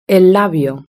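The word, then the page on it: el labio: Spanish (Spain)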